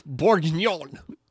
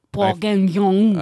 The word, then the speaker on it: bourguignon: I promised that I would look up how to pronounce it because I didn't want to sound like an idiot. – Bourguignon. – I forgot. But y'all know, y'all can picture the word. It's bourguignon.